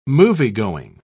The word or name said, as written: moviegoing